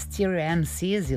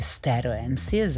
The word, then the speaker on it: Stereo MCs: Tu negde sličan zvuk pre Stereo MCs ili Stereo MCs, zavisi kakav izgovor više volite, jezik koji dosta dugo nismo čuli u emisiji je francuski.